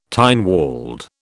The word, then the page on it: Tynwald: British English